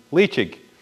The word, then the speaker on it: Ledaig: And that's what I sense in Leitchig. You know, the fact that it's spelled L-E-D-A-I-G and it's pronounced Leitchig is one early indicator of a high-quality single malt scotch.